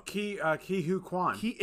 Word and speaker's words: Ke Huy Quan: Key Huy Quan. – Or is it Kui? – I think it's Key Huy Quan.